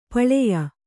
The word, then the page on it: ಪಳೆಯ: paḷeya